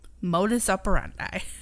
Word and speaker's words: modus operandi: Modus operandi.